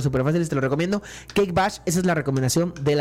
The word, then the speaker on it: Cake Bash: Se llama Cake Bash. Se escribe Kake, C-A-K-E. Bash, como se escucha, con B de burro.